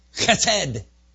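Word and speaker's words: Chesed (חסד): Pronounce it for you like a true Jew. Chesed. Chesed. Chesed.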